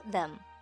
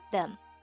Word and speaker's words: the: 注意 put them， put 的 t 可以不完全爆破，所以我们可以读成 put them， put them next to，紧挨着，在这里两个 t 读一个就可以了。 next to，舌头非常忙，对吗？我们在这里读 next to， next to 就可以了。 next to the tree，那注意 the 是一个齿间音，z 咬舌尖。不要读成 the，而是 the tree.